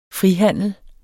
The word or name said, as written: frihandel